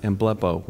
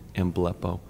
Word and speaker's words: emblepo: Um, it's based on one Greek word, uh, a, a, word uh, that's pronounced emblepo, which isn't the prettiest sounding word, emblepo.